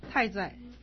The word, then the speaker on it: 太宰: 哎，我们翻开那个五十九页，太宰，这个念成太，特爱太，不是太大太，太宰。